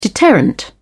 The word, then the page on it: deterrent: British English